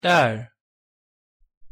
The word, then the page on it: 딸: Korean